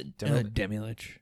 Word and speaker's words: Demilich: It is Demilich. Is that how you pronounce that guy's name? Demilich? – Yeah, the Demilich.